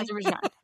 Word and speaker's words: Azerbaijan: I think also, you know, his recent peace negotiation between Armenia and Azerbaijan. Is that how, am I pronouncing that correctly? – Azerbaijan.